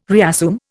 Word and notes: reassume: Indian

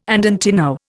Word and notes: andantino: Indian

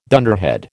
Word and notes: dunderhead: American English

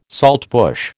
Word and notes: saltbush: American English

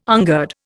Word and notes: ungird: Indian

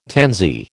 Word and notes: tansy: American English

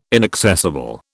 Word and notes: inaccessible: British English

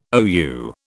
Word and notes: OU: British English

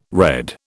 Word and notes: red: British English

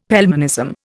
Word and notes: Pelmanism: Indian

